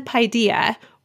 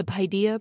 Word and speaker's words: Paideia: Is it Plumfield and Paideia or Plumfield and Paideia? How do you say that? – Oh, that's a great question. – Well, now I say Paideia because that's what you say. If I had just looked at it and said, you know, thought that's Greek, I would say Paideia.